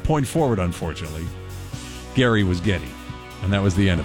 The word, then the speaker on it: Geddy: But she pronounced it Geddy. Geddy. Geddy.